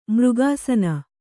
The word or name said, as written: ಮೃಗಾಸನ